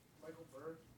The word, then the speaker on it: Michael Berg: Michael Berg, M-I-C-H-A-E-L-B-E-R-G.